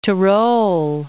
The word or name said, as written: Tyrol